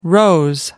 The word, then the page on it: rose: American English